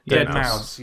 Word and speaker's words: Deadmau5: Deadmau5.